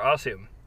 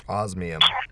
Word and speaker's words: Osmium: So, how is it pronounced? Osmium or Ossium? – Osmium.